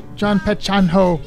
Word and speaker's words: Jaan Pehechaan Ho: The song about a giant puppy that Belinda alluded to earlier was John Petchan... I can't say it right. John Petchanho. Muhammad Rafi.